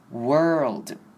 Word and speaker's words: world: 接下来是 world 这个字，之前 记得也有跟大家讲过，这边 world 呢不可以念 or 的声音出来，也是一样要念成的声音。 world，好，接下来的话是 business 事业这个字，不知道大家有没有注意到呢？business 本身字里面有三个 s，分别是 s i 跟后面的 s s 的结尾。